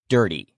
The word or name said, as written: dirty